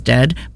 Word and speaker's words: Dehd: Their name is, it sounds like Dead. It is Dead, but they spell it D-E-H-D.